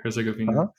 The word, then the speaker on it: Herzegovina: So it sounds like you had your start in Bosnia and Herzegovina. I hope I said that correctly. Yeah. Herzegovina. Uh-huh.